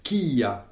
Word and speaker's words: kia: Nous avons vu aussi un petit mot qu'on appellera plus tard un corrélatif et qui se prononce kia, qui s'écrit K-I-A et que l'on prononce kia.